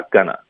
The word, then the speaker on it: gonna: 嗯，这个开头我们有一个 I'm，我们会弱读成 not 的 t 呢，我们要省略， I'm not gonna, 后面 going to 就发 gonna, I'm not gonna, 这四个音节会说的比较快。